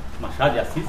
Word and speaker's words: Machado de Assis: Machado de Assis.